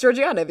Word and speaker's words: Georgiana: You asked me earlier, how do you say Georgiana? And I was like, oh, it's Georgiana, because that's how the narrator of the audiobook said it.